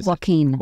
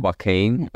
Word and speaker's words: Joaquin: Joaquin. – Joaquin.